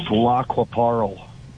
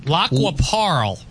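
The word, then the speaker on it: Lac qui Parle: It's pronounced Lacquaparl. – Oh, you see, I don't even know what I'm doing. – Lacquaparl.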